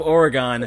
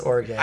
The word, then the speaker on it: Oregon: And the secret shame of Oregon. – One of these days, you got to learn how to pronounce my state. It's Oregon.